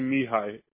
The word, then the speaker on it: Mihai: It's actually Mihai. – Oh, how do you spell that? – M-I-H-A-I. – M-I-H-E-I?